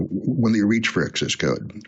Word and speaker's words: xs:code: We, we pronounce yeah, we pronounce it access code, like access code.